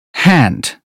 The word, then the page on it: hand: British English